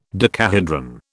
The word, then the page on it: decahedron: British English